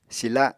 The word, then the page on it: sila: Apatani